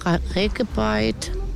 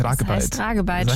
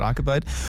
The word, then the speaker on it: Ragebait: Peak, Ragebait. Kenn ich nicht. – Da habe ich gemerkt, ich habe es falsch ausgesprochen. Es heißt eigentlich Ragebeit. – Schäm dich. – Es heißt eigentlich Ragebeit.